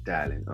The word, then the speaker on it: Dallin: Dorlin Candler. – So you got the last name perfectly. The first name is Dallin. – Dallin, okay.